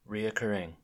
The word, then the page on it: reoccurring: American English